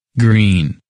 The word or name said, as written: Green